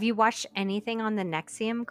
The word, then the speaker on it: NXIVM: So she, well, and it's spelled weird. It's NXIVM. – Yeah. – And it's pronounced Nexium.